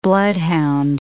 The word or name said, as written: bloodhound